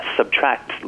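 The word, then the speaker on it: SBTRKT: My favorite album of the year happens to be Subtract's latest album. That's Subtract spelled without vowels and with a K instead of a C.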